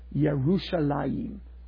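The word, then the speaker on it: Yerushalayim (ירושלים): How do you say Jerusalem in Hebrew? Yerushalayim.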